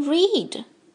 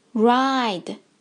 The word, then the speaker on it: ride: 第一个单词 ride，有些小朋友会把它读成 read，请注意字母 i 的发音，它发它的本音 i，跟老师念 i i ride ride。